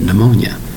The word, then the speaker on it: pneumonia: Now, take care of the spelling of pneumonia. Spelled P-N-E-U-M-O-N-I-A. Three distinct syllables, but north at the beginning. P-N-E-U. That's right. The P is totally silent. That's right. It's silent. The word is pronounced pneumonia.